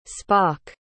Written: spock

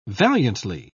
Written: valiantly

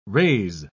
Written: raise

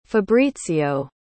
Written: fabrizio

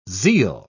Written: zeal

